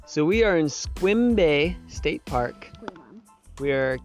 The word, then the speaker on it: Sequim: So yesterday, we biked into Squim. Spelled Sequim, pronounced Squim, we learned.